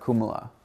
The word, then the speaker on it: Kulmala: So I used to play by my name, Mark Kumala. Mm-hmm. But it's spelled K-U-L-M-A-L-A, and people had a hard time saying it, mm-hmm.